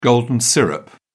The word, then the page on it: golden syrup: British English